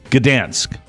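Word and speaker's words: Gdańsk: Baton Rouge, Louisiana, and Gdansk. – Gdansk. Yes. – Gdansk.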